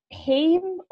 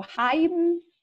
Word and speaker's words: Haim: Like I love it, actually, how you pronounce their name, but Haim or Haim, mm-hmm. H-A-I-M.